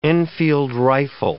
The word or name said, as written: Enfield rifle